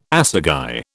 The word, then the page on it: assagai: British English